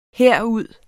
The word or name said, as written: herud